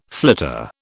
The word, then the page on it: flitter: British English